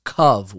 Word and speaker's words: kav: And if you take the word for circumference in this pasuk, which is kaveh, that's how it's spelled, but it's pronounced kav without a hey, and you take the gematria of those two words, look it up in the pasuk.